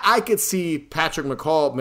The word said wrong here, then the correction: McHale: I'm pronouncing his name right, McCall. I think it's McHale. McHale. I could see him using the Wizard of Oz as an inspiration because it's it's a little obvious.